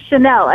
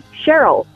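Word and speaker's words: Chenyl: Nobody calls me Chanel. Everybody calls me Cheryl because it's spelled like Cheryl, but with an N. – Oh, yeah. – H-E-N-Y-L is how you spell my name.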